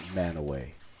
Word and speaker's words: Mantua: Unless you're in Utah, and then that's pronounced Manaway.